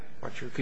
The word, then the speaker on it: Huachuca: The original holder was, I'm not sure how you pronounce it, Huachuca? – Huachuca. – You can correct me. – Huachuca, Your Honor.